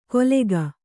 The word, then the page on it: ಕೊಲೆಗ: kolega